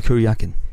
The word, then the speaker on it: Kuryakin: It will star as the two leads, who I can't pronounce, Napoleon Solo, that's one I could, and Kuryakin, I think I got that right. – Ilya Kuryakin.